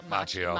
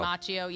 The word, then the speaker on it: Macchio: Joe Pesci, Marissa Tomei, Ralph, how do you say his name? Macchio. – Macchio, yeah.